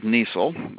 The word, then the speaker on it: Niesel: This is Dave Niesel, rhymes with diesel, and just call me Dave.